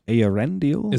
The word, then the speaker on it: Eärendil: Eärendil? – Is it Deal or Dill? – Deal. – It is Deal.